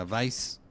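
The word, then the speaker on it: Weisz: Y dice Mariana Castro Mercado, el apellido de Rachel Weiss se pronuncia Weiss.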